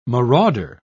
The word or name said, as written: marauder